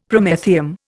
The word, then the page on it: Promethium: Indian